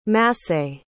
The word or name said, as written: massé